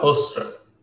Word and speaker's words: Hoekstra: Some Dutch names are really hard to pronounce. And especially if there's a hard G in there or a G. Yeah, foreigners always have some trouble with the G. But also the U, the Hoekstra, yeah, it's difficult to pronounce.